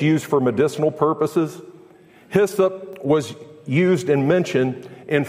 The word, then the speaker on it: hyssop: Hyssop, or as it may have been pronounced in the Middle East, izzib, is a hardy flowering bush-like plant that possesses an aromatic kind of minty fresh scent and a warm, pungent flavor.